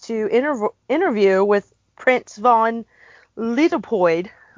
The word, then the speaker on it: Leopold: So it's Leopold.